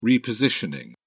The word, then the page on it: repositioning: British English